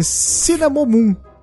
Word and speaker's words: Cinnamomum: Falei, falei, e falei errado. É Cinnamomum. Cinnamomum verum.